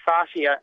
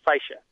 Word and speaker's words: fascia: So fascia and fascia. – Yeah, okay. And fascia would be even more like the Latin, which is fasciae, F-A-S-C-I-A-E. But if you pronounce it like that, I think someone would assume that you were a very learned doctor going back to the Latin sources of your medicine.